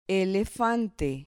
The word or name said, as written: elefante